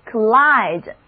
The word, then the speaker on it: collide: 第一个叫做 collide， C-O-L-L-I-D-E，字母 O 的读音是短的，单词的第二个音节是开音节，所以字母 I 仍然发它的本音 I，重音也在第二个音节上。 Collide 这个单词是什么意思呢？